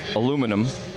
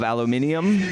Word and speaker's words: aluminium: We have aluminum. Yes. In the colonies. In England, they have aluminium. – Aluminium. – But it's actually spelled differently. So that's not a mispronunciation in as much as it is actually spelled differently, but it's the same. They have aluminium cans with an extra I.